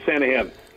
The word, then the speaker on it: Sanaghan: Sanaghan, you were right that time. Yeah, the G is silent, so Sanaghan.